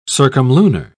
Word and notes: circumlunar: British English

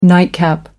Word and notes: nightcap: American English

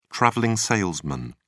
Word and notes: traveling salesman: British English

